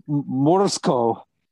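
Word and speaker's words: Morozko: And the actual title is, according to the film, I'm trying to pronounce it the way the people in the film pronounce it, Morsko. Nice. Did I do it? – Nailed it.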